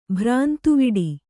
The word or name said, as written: ಭ್ರಾಂತಿವಡೆ